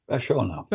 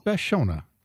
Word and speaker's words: Vachona: And zooming in from his home and still inhabiting the northern and western hemispheres, historian extraordinaire, skipper of the Vachona, or is that Vachona? – Vachona. – Vachona. Long O.